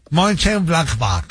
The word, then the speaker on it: Mönchengladbach: Del Mönchengladbach, así exacto, así como lo pronunciaría, así es, así va el alemán. – Mönchengladbach, así.